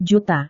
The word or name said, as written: juta